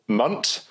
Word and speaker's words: Mwnt: sort of cove in Wales called Munt. Now, because it's in Wales, it's not spelt Munt, it's spelt... – M-W-N-T.